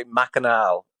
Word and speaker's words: Machinal: You can say it that way, or you can say Machinal. There's both sort of pronunciations as far as I know.